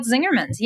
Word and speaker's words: Zingerman's: So hopefully the energy that you got from writing it at Zingerman's, is that right? Did I say that right? – It's called the business is called Zingerman's. Yes.